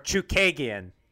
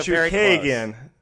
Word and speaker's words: Chookagian: We've got uh, Jessica Andrade was back in action, moving up a weight class, taking on uh, Katlyn Charkugian. Did I butcher that last name? Char- Chookagian. So Chookagian.